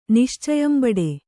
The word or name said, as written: ನಿಶ್ಚಯಂಬಡೆ